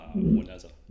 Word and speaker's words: Juaneza: My name is Matt Juaneza. – Juaneza. I was going to ask how you pronounce that. – Juaneza.